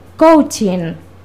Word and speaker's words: coaching: Porque queremos que nos acerque al mundo del coaching.